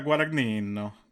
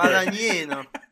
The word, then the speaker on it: Guadagnino: You know, if there's anything that We Are Who We Are by Luca Guadagnino, whatever his pronunciation is. – I believe it's Luca Guadagnino. – Thank you for your super... Guadagnino.